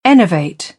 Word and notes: enervate: British English; ə

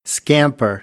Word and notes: scamper: American English; ɚ

